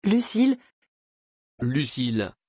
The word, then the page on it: Lucile: French